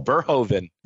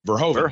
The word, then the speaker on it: Verhoeven: Number 9, Paul How do you call Verhoeven? – Verhoeven.